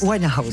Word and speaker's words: Winehouse: Winehouse. – Je le dis bien, hein? – Bien, très bien. La pierre à aspirer comme il faut.